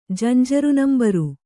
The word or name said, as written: ಜಂಜರುನಂಬರು